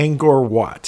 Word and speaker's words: Angkor Wat: Angkor Wat.